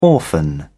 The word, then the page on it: orphan: British English